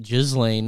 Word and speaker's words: Ghislaine: That's how you pronounce it, Ghislaine. That's how I remember it. It's like a lane of Ghislaine.